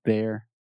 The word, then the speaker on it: there: There, there, there. – There, there, there. – Okay. – Awesome. And with the TH. So there, there, there. Your tongue is outside your mouth.